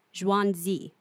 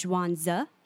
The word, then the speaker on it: Zhuangzi: I'm not 1,000% sure I'm pronouncing it right, but I looked it up in maybe 20 different sources. So it appears like the text we're talking about, the name of the title, is Zhuangzi.